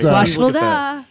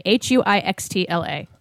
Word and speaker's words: Huixtla: H as an A. – What? – Huix. X is an X-ray. Tla. Huixtla.